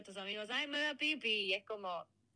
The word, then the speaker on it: Phi Phi: Seguro te referís a las islas Fifi, que en realidad se pronuncia más como Pipi, sin marcar la F.